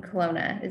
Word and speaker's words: Kelowna: Today I'm here with Brittany Washstrom, who owns Intuitive Home in Kelowna.